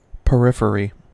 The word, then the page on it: periphery: American English